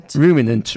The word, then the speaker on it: Ruminant: Ruminant, released early this year.